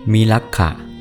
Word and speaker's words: มิลักขะ: มิลักขะมิลักขะหมายถึงคนป่าเถื่อนชาวป่าชาวเขาใช้ว่ามิลักขุก็มีมิลักขะ